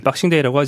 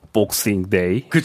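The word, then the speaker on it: Boxing Day: 예, 역시 그 아나운서께서 말씀하시는 우리나라 표준 발음은 네. 미국식이라서 우리는 박싱데이라고 그 하지만 네. 영국에 있는 이건 기자는 복싱데이. 그렇죠. – 복싱 이렇게 발음합니다. 네.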